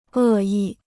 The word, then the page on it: 恶意: è yì